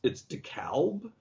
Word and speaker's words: DeKalb: And Jason kind of nervously presses the talk back button and says, Mr. Shatner, that was great, but I'm afraid you mispronounced the name. It's DeKalb.